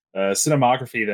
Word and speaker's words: cinematography: Yeah, uh, I would say, given with how tedious the opening was, um, but also just the fantastic, just like cinemog- uh, uh, cinematography that what I'm saying that wrong.